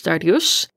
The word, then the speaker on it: Dariush: He also calls Darius Dariush, which is the Persian pronunciation of his name. And even though Darius never thought of himself as Dariush because he's grown up in the U.S. and always said his name the anglicized way,